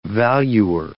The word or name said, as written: valuer